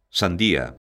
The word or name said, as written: sandía